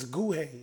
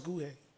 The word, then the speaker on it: Nguhi: The way you spell her name is N-G-U-H-I. It's Guhe. It's pronounced Guhe. – Guhe. – N-G-U-H-I.